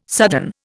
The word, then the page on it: sudden: Indian